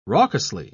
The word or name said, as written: raucously